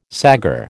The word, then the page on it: saggar: American English